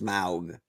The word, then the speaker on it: Smaug: I left you here with smog. – I think it's actually pronounced smaug.